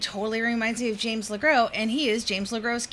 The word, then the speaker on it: LeGros: Is it James LeGros? Is the S silent? – It is silent, yeah.